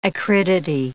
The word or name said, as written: acridity